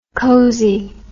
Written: cosey

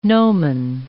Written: nomen

